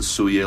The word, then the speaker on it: Soulier: But anyways, he pronounces it Suye, like the French word for shoe.